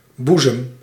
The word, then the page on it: seins: [le sɛ̃]